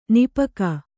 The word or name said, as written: ನಿಪಕ